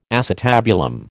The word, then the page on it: acetabulum: American English